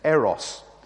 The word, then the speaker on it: eros: The first was eros, or as it's properly pronounced, eros.